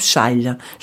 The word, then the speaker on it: xale: Et euh, dans, le, dans, dans un mot, le X d'ailleurs en portugais se prononce che. Exactement, comme le mot par exemple chal. chal. – Le châle, c'est voilà, X A L e, exactement.